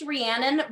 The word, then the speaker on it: Rhiannon: It's a hard name. It's pronounced Rhiannon, but truly every day somebody calls me something different.